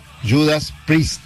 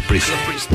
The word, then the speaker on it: Priest: Se pronuncia Judas Priest. Priest. – Judas Priest. Vamos, no, yo dije Priest. – Lo vimos dos veces. – Es Priest. Es cierto.